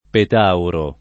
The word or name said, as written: petauro